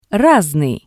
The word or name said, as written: rasnii